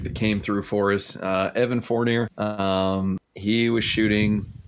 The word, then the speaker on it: Fournier: And someone corrected me that spoke French, and it's Evan Fournier.